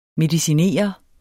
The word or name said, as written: mediciner